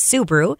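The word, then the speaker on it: SEUW Brew: It's spelled S-E-U-W, like Sioux Empire United Way, but pronounced Sioux Brew.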